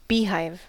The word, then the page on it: beehive: American English